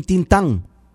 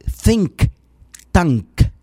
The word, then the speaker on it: think tank: Anteriormente nos habló de un tintán, del tintán, en lugar de think tank, con K, señor presidente, al final.